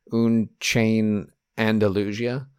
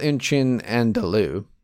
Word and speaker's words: Un Chien Andalou: But it's called Un Chien Andalou. He's kind of mispronouncing it, like he's adding some flair to it there.